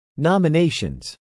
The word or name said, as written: nominations